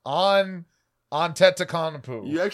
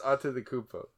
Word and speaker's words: Antetokounmpo: His name is Giannis Antetokounmpo. – You got the first part right. Giannis Antetokounmpo.